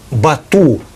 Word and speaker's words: Бату: Бату или Бату, правильно? – Бату, средневековое монгольское произношение, бат, современное монгольское, означает сильный.